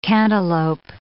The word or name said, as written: cantaloup